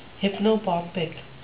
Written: hypnopompic

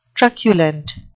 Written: truculent

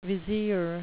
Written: vizier

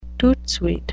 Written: toot sweet

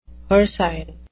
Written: hircine

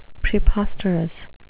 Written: preposterous

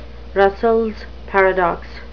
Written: russells paradox

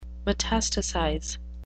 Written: metastasize

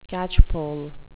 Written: catchpole

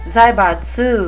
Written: zaibatsu

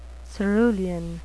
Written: cerulean

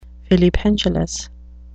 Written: filipendulous

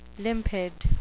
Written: limpid

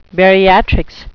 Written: bariatrics

